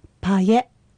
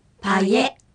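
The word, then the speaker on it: パイェ: 続いてパエパエ、行くという意味です。 – いえとえを大きく発音しないように注意しましょう。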